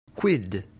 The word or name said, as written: quid